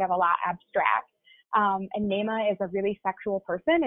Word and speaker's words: Naamah: Okay, second, I'm going to choose Naima by Sarah Blake. That is spelled N-A-A-M-A-H. – Okay.